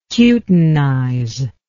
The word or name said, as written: cutinization